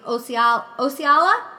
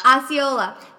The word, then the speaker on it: Osceola: Osceola, did I say that right? No? – Osceola.